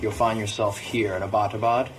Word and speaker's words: Abbottabad: We were corrected profusely by Pakistanis that it's Abbottabad.